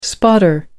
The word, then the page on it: spotter: American English